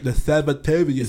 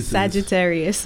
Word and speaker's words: Sagittarius: The Sabbatarius. – Sagittarius.